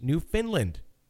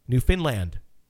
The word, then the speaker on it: Newfoundland: It's pronounced New Finland with the emphasis on land. Newfoundland. – Mm-hmm. – Did I do it right? Newfoundland? – I think so.